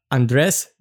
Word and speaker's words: Andres: Today I'm here with Andres.